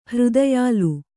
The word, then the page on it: ಹೃದಯಾಲು: hřdayālu